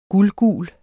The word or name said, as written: guldgul